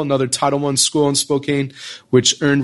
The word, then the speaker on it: Spokane: It's pronounced Spokane. You did pretty well.